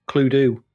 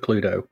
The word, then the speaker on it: Cluedo: Well, Cluedo, which is the right pronunciation for Cluedo. – Cluedo. – Cluedo. – Why is it Cluedo? – Because it's D-O. You don't say... – Like Nintendo, is it? You don't go on your Nintendo.